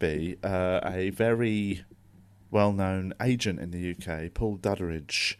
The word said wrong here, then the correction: Duddridge: Um, I don't know why I added a sort of syllable into his name there. It's Duddridge, um,